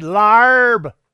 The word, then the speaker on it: larb: I said, "Larb." And she said, "Larb." She wrote it down. It's spelled L-A-R-B.